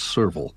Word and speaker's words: serval: In African, I looked up the uh, pronunciation of this several times, and I think it's serval, right? – Yes serval, mm-hmm.